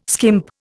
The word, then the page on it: skimp: Indian